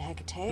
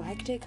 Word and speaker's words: Hecate: One is spelled with a C, one is spelled with a K, and the two pronunciations are Hecate and Hecate. Again, both are technically correct. So I sometimes kind of swap between the two, but they're both the right pronunciation still. So Hecate comes from the ancient Greek pantheon.